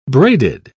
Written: braided

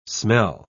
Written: smell

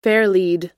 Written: fairlead

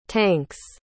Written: tanks

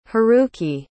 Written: haruki

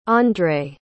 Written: Andrey

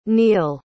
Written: neill

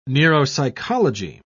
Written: neuropsychology

